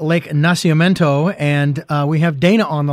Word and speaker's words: Nacimiento: Yeah, Nacimiento.